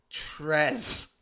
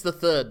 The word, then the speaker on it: Villano III: Trez. – I think it's the third, not Trez. I think we mispronounced it during all that time.